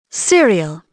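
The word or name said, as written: cereal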